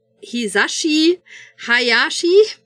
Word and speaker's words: Hisashi Hayashi: Das Spiel ist von Hisashi Hayashi. – Ja, hast du richtig ausgesprochen.